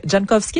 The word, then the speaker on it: Jankowski: mais aucun n'a été repêché aussitôt que Marc Jankowski, est-ce que je le prononce comme exactement.